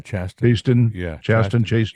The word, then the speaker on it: Chasten: Chasten.